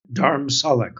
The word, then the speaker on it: Dharamsala: Yes, Dharamsala, correct.